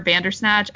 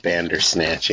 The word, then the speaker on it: Bandersnatch: It kind of reminded me of, what was that show on Netflix, the Bendersnatch with Black Mirror, Bandersnatch? I'm probably saying that wrong. Do you know what I'm talking about? Yeah. – Yeah, I think that's what it was called, Bandersnatch, yeah.